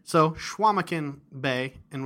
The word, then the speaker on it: Chequamegon: Okay, that's not how you say it. – All right, give me the proper pronunciation. – Schwamigan. – Schwamigan. Okay.